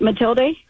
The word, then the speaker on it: Matilde: Is it Matilde? – Okay, Matilde. Okay, got it.